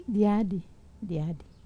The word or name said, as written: diadi